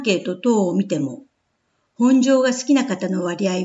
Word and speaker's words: 本庄: 以前にも本庄駅の自動音声のアナウンスが本庄と発音されていることに対し、たくさんのご意見が寄せられました。市としても JR に申し入れ、私たちが耳慣れた本庄に戻してもらいました。